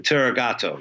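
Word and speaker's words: tárogató: I know you've also got on this album, I got to make sure I'm saying it right, a Hungarian tarogato? – The tarogato, yes, this instrument.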